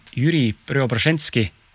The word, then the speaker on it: Jüri Preobraženski: seekordseks ausa mehe podcast'i külaliseks on Jüri Preobraženski.